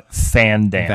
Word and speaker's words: Van Damme: I think it's pronounced Van Damme. – Van Damme? Did I not speak that correctly? – Jean-Claude Van Damme. – That's correct. – That's better.